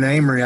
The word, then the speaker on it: Amory: Well, you know, I'm from Amory, Mississippi, A-M-O-R-Y, but it's pronounced Amory.